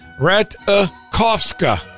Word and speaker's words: Ratajkowski: Emily Ratajkowski revealed the correct pronunciation of her last name is Ratajkowska.